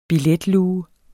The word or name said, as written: billetluge